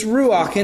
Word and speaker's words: ruach: So in Hebrew, I believe it's pronounced ruach. It's ruach, and that could be the wind, or it could be the spirit, or it could be the breath.